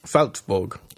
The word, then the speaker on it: Salzburg: Salzburg, am I pronouncing that right? – Salzburg.